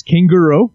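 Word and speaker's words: canguro: Yeah, it's often mispronounced, I guess. It's kangaroo, which is Spanish for kangaroo.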